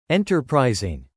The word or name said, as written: enterprising